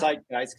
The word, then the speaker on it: Zeitgeist: It's in German, it's Zeitgeist because the Z is pronounced like a T.